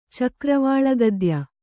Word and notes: ಚಕ್ರವಾಳಗದ್ಯ: kannada

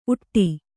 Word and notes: ಊಟ: kannada

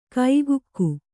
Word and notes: ಕೈಗುಕ್ಕು: kaigukku

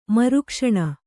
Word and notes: ಮರುಕ್ಷಣ: Kannada

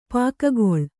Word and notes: ಪೊಗೆಗೊಳ್: Kannada

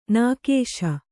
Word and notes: ನಿಕಸ: nikasa

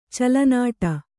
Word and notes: ಚೆಲ್ಲೆನೋಟ: celle nōṭa

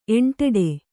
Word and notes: ಎಂಟಡಿ: eṇṭaḍi

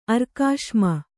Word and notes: ಅರ್ಕಾಶ್ಮ: arkāśma

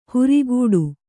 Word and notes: ಹುರಿಗೂಡು: Kannada

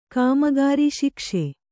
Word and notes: ಶೈಕ್ಷ: śaikṣa

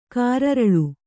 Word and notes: ಕಾರಿರುಳು: kāriruḷu

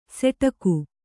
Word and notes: ಸೆಟಕು: seṭaku

